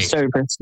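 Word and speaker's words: Stover's: Is it Russell Stowe? What's the Russell? Stovers. Okay. – Stovers.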